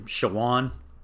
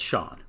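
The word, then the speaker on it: Shawon: I grew up calling him Shawan because that's how it's spelled, but in everything I've seen, it's Sean. And so I think we're going with Sean.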